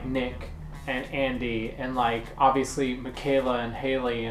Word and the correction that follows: Hallie: and that kind of thing. And Hallie. Sorry. – And no, it's fine. I always say her name wrong.